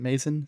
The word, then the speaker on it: Mazin: And this is, again, directed by Johan Rank and written by Craig Mazin. Is it Mazin? Is that how you say it? – I think it's Mazin, yeah.